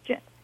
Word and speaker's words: Jinn: Jinn? Is that? – That's right. It's Jinn.